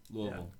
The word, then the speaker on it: Louisville: Some people say Louisville, it's Louisville.